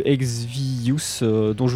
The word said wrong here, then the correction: Exivious: vous parlais, pardon, je le prononce très très mal, Exivius, pardon, voilà, E-X-I-V-I-O-U-S, donc deux groupes dont certains membres proviennent,